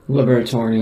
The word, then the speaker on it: Liberatore: but is it Liberatore? Like, the Liberatore, yeah.